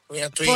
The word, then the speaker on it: Antoine: Poi la cantava pure uno francese, Antonin. Antoine. – Forse Antoine. – Antoine, scusa. Che pronuncia che c'ho? Antoine, sì. – Antoine, non Antonio.